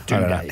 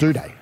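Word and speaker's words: Doedee: Do-day or Dodo? – Do-day. – How do you say it? – Do-day. – Dud day, I believe.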